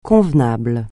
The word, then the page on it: convenable: francais